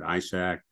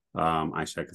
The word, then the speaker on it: Ishak: Again, my name's Alfred Ishak. Ishak is spelled I-S-H-A-K.